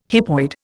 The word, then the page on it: hypoid: Indian